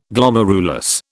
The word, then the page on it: Glomerulus: British English